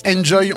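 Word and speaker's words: And'Joy: Merci beaucoup, Nicolas et Géraldine du groupe Enjoy. On vous retrouve sur la page Facebook Enjoy. – Yes. Hein. Exactement. – Ça s'écrit A-N-D apostrophe J-O-Y.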